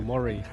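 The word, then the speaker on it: Moni: 好像这个词叫做 Mori，是指我这发音是对的嘛？所以我想请大使阁下给我一个校正，是不是应该这样发音，叫 Mori 还是叫 Molly？ – Yes, it is. You are pronouncing it properly.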